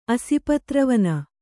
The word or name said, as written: ಅಸಿಪತ್ರವನ